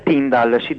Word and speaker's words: Tyndale: La pronuncia è Tyndall, penso si scriva Tyndale.